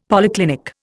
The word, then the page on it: polyclinic: Indian